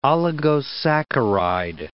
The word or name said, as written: oligosaccharide